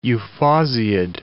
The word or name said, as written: euphausiid